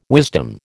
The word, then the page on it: wisdom: American English